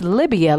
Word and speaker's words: Libya: but it's Libya, like the country, BaaQar, BaaQar.